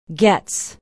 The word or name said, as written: Goetz